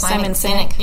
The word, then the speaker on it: Sinek: Simon, and I hope I'm pronouncing that right, Sinek, S-I-N-E-K. Does that sound like Simon Sinek? – Yeah.